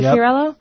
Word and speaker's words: Fiorello: Is it Fiorello? – Yep.